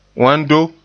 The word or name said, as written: wando